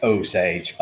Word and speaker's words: Osage: By the way, it's pronounced Osage, by the way. Just I screwed it up.